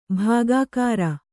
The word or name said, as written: ಬಗ್ಗರೆ